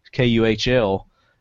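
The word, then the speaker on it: Kühl: KUHL. It had two little dots over the U, and I have since learned that you pronounce that as oo. So the pants are cool.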